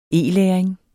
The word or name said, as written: programtype